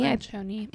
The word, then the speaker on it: Lencioni: And we talk about this book in general written by Patrick Lencioni. – Lencioni. – Is how she pronounced it.